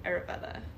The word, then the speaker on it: Ayurveda: How, can, how do you pronounce it? Ayurveda. Ayurveda. Ayurveda. – So like I. – Yeah. – Er. Veda. Ayurveda. I would say Ayurveda.